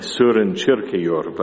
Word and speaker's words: Søren Kierkegaard: It's a theme that I take from the Danish philosopher Søren Kierkegaard, or I understand he should be pronounced something like Søren Kierkegaard, but I won't do that.